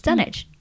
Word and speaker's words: Dunwich: But I've been saying it wrong forever. It's Dunwich.